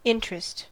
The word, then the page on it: interest: American English